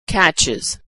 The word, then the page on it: catches: Iz